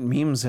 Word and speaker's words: meme: And the way I can hear some of our Bladtcast listeners typing in what memes are. It's all right. – Pronounced meme. – It is meme.